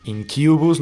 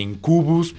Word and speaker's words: Incubus: Incubus, no digan Incubus, por favor, es Incubus.